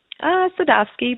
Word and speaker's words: Sadovsky: Sadovsky, but yeah, pretty close.